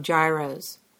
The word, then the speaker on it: gyro: And who doesn't love gyros? Insider tip, the correct pronunciation is gyro.